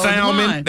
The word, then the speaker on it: salmon: Salmon. That's salmon. – Salmon has that. Well, that L is supposed to be silent, ain't it? – Kind of, sort of.